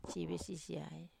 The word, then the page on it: vesisiai: Wamesa